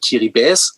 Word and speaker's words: Kiribati: Name Bubuti ähm, stammt vom Inselstaat Kiribati, eigentlich Kiribäs ausgesprochen richtig,